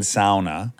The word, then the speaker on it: sauna: I like saying sauna. – Oh, yep. – Pronouncing it correctly. – You do like that. – The Finnish pronunciation.